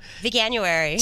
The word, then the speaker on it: Veganuary: I always say it wrong. – Veganuary.